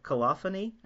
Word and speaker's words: colophony: Is it colophony, I think? – How I say it.